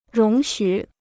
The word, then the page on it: 容许: róng xǔ